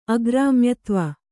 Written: ಅಗ್ರಾಮ್ಯತ್ವ

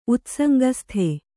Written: ಉತ್ಸಂಗಸ್ಥೆ

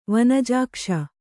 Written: ವನಜಾಕ್ಷ

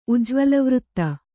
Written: ಉಜ್ವಲವೃತ್ತ

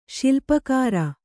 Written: ಶಿಲ್ಪಕಾರ

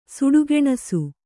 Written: ಸುಡಗೆಣಸು

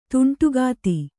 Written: ಧಾಂಡಿಗಿತ್ತಿ

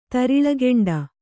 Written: ತರಿಳಗೆಂಡ

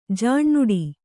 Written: ಜೇನಿಡು

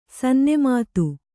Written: ಸನ್ನುಮತ

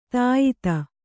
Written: ಧೀಯುತ

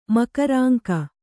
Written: ಮಕರಾಂಕ

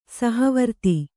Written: ಸಹವರ್ತಿ

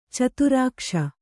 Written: ಚತುರಾಕ್ಷ

